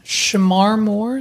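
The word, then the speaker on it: Shemar Moore: Shamar Moore.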